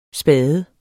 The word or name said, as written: spade